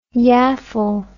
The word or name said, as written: yaffle